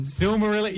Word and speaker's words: Silmarillion: And the Simmalacrum? – No. – The Silmarillion. – Silmarillion. You pronounced it correctly.